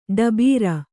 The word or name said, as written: ಟಬರು